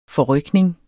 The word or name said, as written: forrykning